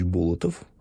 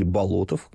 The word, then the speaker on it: Болотов: В составе русских войск там воевал Андрей Тимофеевич Болотов, или Болотов. Сейчас вроде бы историки решили делать ударение на другой слог.